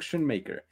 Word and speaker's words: Schoonmaker: Ayúdenme con la pronunciación del de Michigan. Se llama Luke Schoonmaker. O ¿no? Yo le diría Schoonmaker. – ok, ahí está. Luke Schoonmaker.